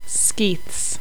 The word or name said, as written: Skeiths